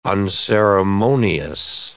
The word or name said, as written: unceremonious